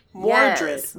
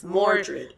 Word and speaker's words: Mordred: Because I haven't heard her name Mordred. – Mordred.